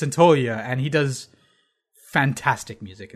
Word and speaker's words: Santaolalla: It's Gustavo Santaolalla, which I had to look up how do you say that word, because it's spelled Santaolalla, and you just say Santaolalla.